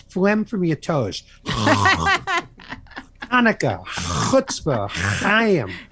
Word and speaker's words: Chutzpah: Chutzpah. By the way, thank you very much for saying that correctly.